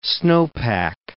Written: snowpack

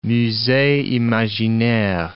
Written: musee imaginaire

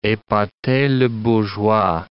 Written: epater le bourgeois

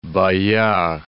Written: Bayard

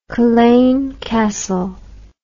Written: culzean castle

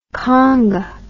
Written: khanga

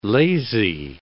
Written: lazy

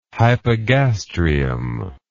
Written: hypogastrium

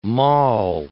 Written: maul

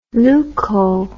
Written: leuco

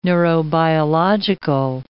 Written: neurobiological

